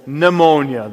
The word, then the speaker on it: pneumonia: For example, pneumonia, the silent P.